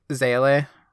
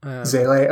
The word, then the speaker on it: SEALE: That's how I've always thought of it, but maybe it probably is SEALE. – Yeah. The like actual German word I think is even slightly more, but I would just say it like SEALE. – SEALE. Okay. This is now the official Ghost Divers pronunciation.